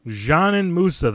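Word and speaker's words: Džanan Musa: A little bit later, we're going to talk to Adam Arbrecht of Locked On Nets about Janin Musa. That's right, Janin Musa. That's how to pronounce it. We checked for you.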